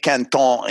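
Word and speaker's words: Quinton: From René Quintin, right? – René Canton in French, okay? – Canton.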